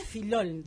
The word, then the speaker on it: Filiol: Bueno, con Ubaldo Filiol. – Filiol, sí. El pato. – Sí, pero viste que es, en realidad Filol sería el pronunciado.